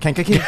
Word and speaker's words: Kankakee: How do you pronounce it? Like he did, just not as slow, but Kankakee.